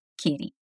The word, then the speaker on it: kitty: Apesar de kitty ser escrito K-I-T-T-Y, a gente vai pronunciar os T's como se fosse um R.